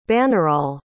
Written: bannerol

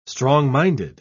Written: strong-minded

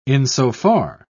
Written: insofar

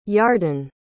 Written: yardan

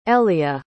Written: elea

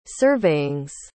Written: surveyings

